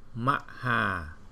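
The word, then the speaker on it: Mahā: Chẳng hạn như là Maha, cái chữ ma đầu á là mình đọc rất là chậm. Nó thành chữ má là má. Rồi chữ ha đó là nó có dấu gạch ngang cho nên mình đọc là ha. Mình ráp lại là má ha.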